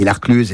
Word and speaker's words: l'Arclusaz: On dit l'Arcluse, évidemment, ça s'écrit l'Arclusaz avec un S.